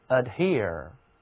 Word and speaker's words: adhere: 它的读音就是和 here 这里这个单词是一样的 adhere。